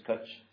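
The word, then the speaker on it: cwtch: Cutch. Is that how you say it? Cutch? – Yeah.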